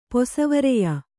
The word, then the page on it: ಪೊಸವರೆಯ: Kannada